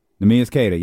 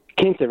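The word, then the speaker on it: Nemias Queta: Is that how you say his name? – Nemias Cater. Yeah. – Kinter, right? – No, Cater, almost.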